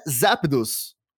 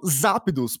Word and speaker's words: Zapdos: a pronúncia americana é Zapdos, com, como se fosse Zé. Zé com E. Uhum. É aquela pronúncia mesmo. E ele conseguiu deixar como Zapdos, porque é o que faz sentido aqui no Brasil.